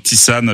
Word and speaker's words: Tisane: Et donc Tisane avec le titre « Baby Business ». C'est bien comme ça que ça se prononce.